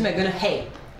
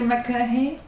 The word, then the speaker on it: McConaughey: Matthew McConaughey. – Non, non, on dit pas ça. On dit Matthew McConaughey. – Matthew McConaughey.